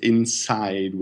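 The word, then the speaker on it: Inpsyde: I just recently learned from somebody speaking very good English that it would be pronounced inside, which with the silent P. – Ah, okay. – But we pronounce it inside.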